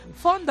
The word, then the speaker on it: von: No, perdona, esto es von. Y si me dices von, es F.